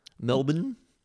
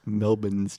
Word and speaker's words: Melbourne: It's just how much do you enunciate the in Melbourne? – Because I think people over-pronounce it. – You think people over-pronunciate the Melbourne? – Yeah, the lack of the R.